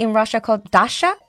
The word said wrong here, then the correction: dacha: Am I pronouncing that right? It's like a summer house sort of thing, like outside of it's dacha.